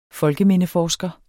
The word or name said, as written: folkemindeforsker